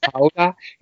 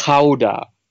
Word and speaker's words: Gouda: It's not Gouda. It's Gouda. I'm half Dutch, so I can't.